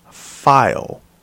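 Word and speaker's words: faille: Can I hear it pronounced? File.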